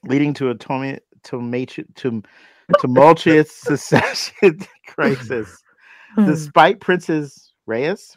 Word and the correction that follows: Rhaenyra: what is it? – Rhaenyra being the rightful heir, Queen Go.